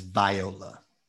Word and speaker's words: Viola: The correct pronunciation is Viola. – Viola. – Viola Davis. – Like the instrument.